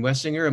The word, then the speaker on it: Wessinger: Catherine Wessinger.